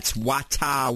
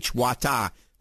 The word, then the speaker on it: Wattahh: That's Wata water. It's spelled W-A-T-T-A-H-H. Wata.